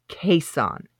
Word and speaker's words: Caisson: Okay, I got to say this right. Quezon. It's been a while. Quezon. C-A-I-S-S-O-N was his name.